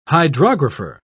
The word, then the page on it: hydrographer: British English